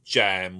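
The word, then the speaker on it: jamb: So M inside jab gives you jam with a silent B, which is a supporter in the sense of an upright beam that supports the lintel of a door or a window.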